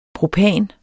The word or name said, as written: propan